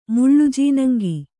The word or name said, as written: ಮುಳ್ಳುಜೀನಂಗಿ